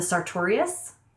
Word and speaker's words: sartorius: There's also this really awesome muscle called the sartorius. And you can kind of say it like Notorious B.I.G.